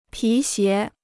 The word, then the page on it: 皮鞋: pí xié